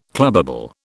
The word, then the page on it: clubbable: British English